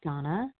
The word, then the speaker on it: Dana: This is Donna B., as in boy. I spell my name D-A-N-A, but it's pronounced Donna.